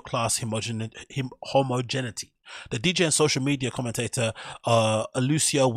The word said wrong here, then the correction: Aloisio: Wilmoth, how do you say his name? Aloisio Wilmoth, aka He Valencia, big up him.